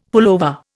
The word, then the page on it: pullover: Indian